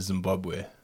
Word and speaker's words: Zimbabwe: I think the correct pronunciation is Zimbabwe, to be honest. – Right. – That's the local pronunciation.